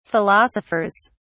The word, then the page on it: philosophers: British English